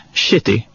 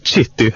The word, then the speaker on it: Chitty: Och där träffade han även en tjej som heter Kathy Shitty. Kathy Shitty. Eller hur hade du uttalat det? – Alltså jag hade nog sagt Chitty, alltså CH.